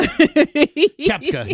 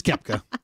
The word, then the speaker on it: Koepka: Koepka, yeah. It's just Koepka.